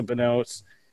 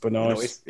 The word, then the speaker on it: Benoist: Like, it made a star out of all those all the actors, Melissa Benoist, uh, I always butcher her name, Benoist. – Benoist.